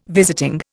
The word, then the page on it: visiting: Indian